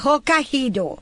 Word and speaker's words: Hokkaido: Hokkaido. Se dice así, ¿verdad, Teody? Bueno, Teody me dirá que en inglés. Hokkaido, sí, porque me acordé del Aikihiro, así que debe ser Hokkaido.